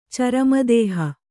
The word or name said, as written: ಚರಮದೇಹ